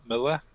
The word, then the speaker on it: Mueller: Yeah, it's spelt Mueller, but we pronounce it Miller.